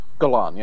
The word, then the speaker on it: Golan: Which is really weird, because the guy who produced it is Menahem Golan. – Golan, yeah. – Is that how you pronounce it? – Golan, yeah.